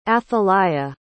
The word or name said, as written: athaliah